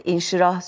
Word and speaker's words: İnşirah: E, bu çok e, Düha suresi, İnşirah suresi, Tin suresi. Evet. Doğru telaffuz ediyorum çok doğru, değil mi? Çok doğru.